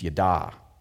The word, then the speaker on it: yada: The Hebrew word for to know something is pronounced yada, which is really funny when the Seinfelds brought it up again over and over again, like yada, yada, yada, same word, right?